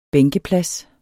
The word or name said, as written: bænkeplads